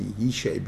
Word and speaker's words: Yishai: And therefore, if you really look at the words, you'll see that the thing there, you know, how did Jesse, Yishai, become Jesse? Right? You ever think about that? Jesse, Yishai. It's got to do with Jesse. Because J and Y were the same sound. So it's Jesse. Yishai, right? Yesha. Who says it's a C? It's an I sound, I sound at the end. Yesai, Yesai. That's the way it is pronounced.